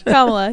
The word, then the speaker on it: Kamala: Kamala. Yeah, I quickly corrected it there. I have a hard time with her name.